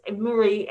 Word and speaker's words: Marie: Uh, my name is spelled A N, not with an E, uh, space Marie, M A R I E, uh, Adams, A D A M S, and sometimes it's used a hyphen but never E.